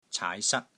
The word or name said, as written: caai2 sat1